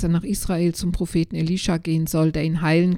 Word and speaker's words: Elisha: Aber auf Hebräisch ist Elisa ein Männername. Eigentlich wird er Elisha ausgesprochen.